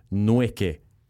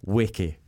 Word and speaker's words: Nweke: For a long time, people were calling you Nweke. – Yeah. – But it's Weke. – It's Weke, yeah. The N is silent, very much silent.